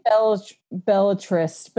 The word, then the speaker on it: Belletrist: We say Belletrist, but you know, that's just kind of how we say it. Trist, I guess, would be sad. It's more T-R-I-S-T.